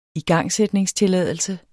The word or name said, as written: igangsætningstilladelse